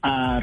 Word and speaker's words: Revieve: Eh, ¿La pronunciamos bien, Reviaf? – Revive, Reviv.